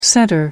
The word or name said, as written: center